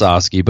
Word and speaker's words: Lisoskie: It's Lisoskie, but you can call me Lewinsky.